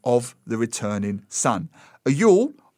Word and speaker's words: Yule: Well, there was the Feast of Yule, J-U-U-L. It looks like Jewel, but it's pronounced Yule, hence Yule log.